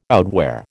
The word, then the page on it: outwear: American English